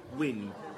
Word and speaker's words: Wynne: Wynne. – Oh, Wynne. – W-Y. – Oh, well, Chinese. – Double N-E. – Oh, right. – No, not Wing. No.